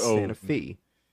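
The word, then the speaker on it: Sante Fe: It's spelled S-A-N-T-E space F-E, but if you call it Santa Fe, they'll hang you. You don't want to do that. It's Santa Fe.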